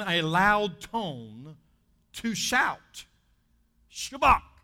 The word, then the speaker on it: Shabbat: And if you say it right, you got to kind of get a kernel of popcorn in your throat and say, Shabbat.